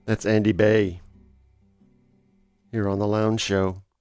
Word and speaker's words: Bey: Also, right before the top of the hour, we heard more Andy Bay. His last name is spelled B-E-Y.